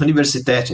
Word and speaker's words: Universität: Tem pós-doutorado pela George August, agora eu não sei universitar, não sei como é que eu falo isso, Göttingen, na Alemanha. Falei certo? – É, é tá certo, Universität eles falam, mas tá tudo bem.